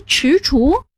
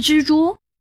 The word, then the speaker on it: 踟蹰: 那两个字读踟蹰，不是蜘蛛。 – 踟左边是足字旁，右边是知道的知，也难怪你读作知，正确读音是踟。